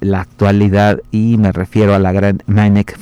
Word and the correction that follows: Mieneke: van der Velden, Mieneke, así se escribe su nombre, Mieneke, Van der Belden.